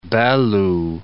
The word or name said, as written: Belo